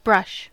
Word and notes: brush: American English

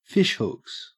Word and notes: fishhooks: Australian English

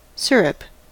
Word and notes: syrup: American English

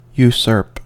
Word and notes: usurp: American English

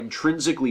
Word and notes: intrinsically: American English

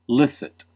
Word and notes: licit: American English

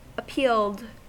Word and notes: appealed: American English

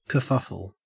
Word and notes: kerfuffle: Australian English

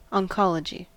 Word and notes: oncology: American English

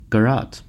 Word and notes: garrote: American English

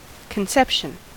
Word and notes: conception: American English

